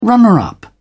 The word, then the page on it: runner-up: British English